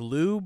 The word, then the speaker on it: Liu: I know how to say his first name, but the last name is like Liu, but like you have to have just a tiny bit of the I sound in Liu. – Liu.